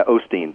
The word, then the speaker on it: Osteen: Yeah, well, I might have a little bit of that Texas drawl because I said Joel Osteen and you said Joel Osteen. I'm not sure which way it's pronounced. I guess that's my Wisconsin accent coming through and calling him Osteen. But I'll try to remember to call him Joel Osteen. Is that the typical pronunciation? Is that how he calls himself? – Yeah, Osteen.